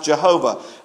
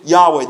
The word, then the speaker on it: Yahweh: This is Yahweh, or as we sang, sometimes it's pronounced Jehovah. But the Hebrew pronunciation, Yahweh.